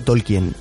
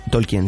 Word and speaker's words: Tolkien: Vamos a ver, yo siempre he dicho Tolkien con, pronunciando la E. ¿Tolkien? Tolkien, sí.